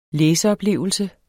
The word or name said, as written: læseoplevelse